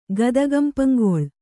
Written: ಗದಗಂಪಂಗೊಳ್

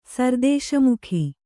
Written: ಸರ್‍ದೇಶಮುಖ

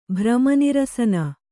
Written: ಭ್ರಮನಿರಸನ

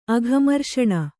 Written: ಅಘಮರ್ಷಣ

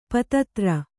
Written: ಪತ್ತೆದಾರ

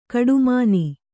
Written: ಕೋಟೆಮನೆ

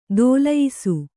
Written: ದೊಲ್ಲಯಿಸು

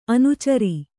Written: ಅನಾಚಾರಿ

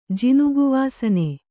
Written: ಜಿನುಗುವಾಸನೆ

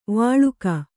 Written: ವಳಕು

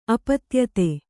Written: ಅಪತ್ಯತೆ

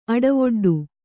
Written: ಅಡವೊಡ್ಡು

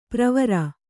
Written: ಪ್ರವರ

